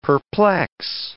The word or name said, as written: perplex